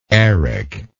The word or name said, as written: Eric